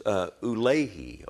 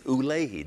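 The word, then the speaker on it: Ulai: And I saw in the vision that I was by the river And it's actually pronounced Ulehi, okay? Now, don't you just hate to pronounce all those words sometimes? But it's Ulehi. Doesn't look like it says that, but that's exactly what it is.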